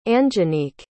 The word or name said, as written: Anjanique